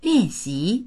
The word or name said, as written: lian4xi2